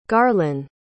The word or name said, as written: garlen